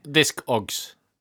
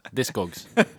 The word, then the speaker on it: Discogs: Discogs. Yeah, it's not like the exaggeration or ignition thing. You pronounce it differently. Discogs.